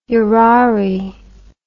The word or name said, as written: urari